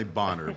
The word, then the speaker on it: Bonner: It's B-O-N-N-E-R. Yeah. It's definitely boner. But the second N could be silent.